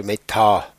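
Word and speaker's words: Metta: Das heutige Thema ist Meta oder Meta, wie es richtig ausgesprochen wird scheinbar.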